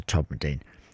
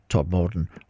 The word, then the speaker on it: Todmorden: Tomorrow I head 30 miles north to Todmorden. If you're from the south, you pronounce it rather more commonly Todmorden, or if you live there, it's just Todd.